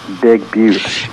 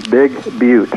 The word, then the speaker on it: Big Butte: And you know, a lot of those Eastern people, they keep mispronouncing that all the time, and they keep saying Big Butt. – That's true. Only you and I know the real pronunciation, Big Butte.